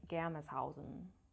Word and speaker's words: Germershausen: So, But I have a German boss at work, and she told me the correct way to say my last name, and it's something like Germershausen. I don't know, something like that.